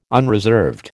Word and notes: unreserved: American English

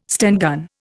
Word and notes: Sten gun: Indian

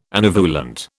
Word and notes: anovulant: British English

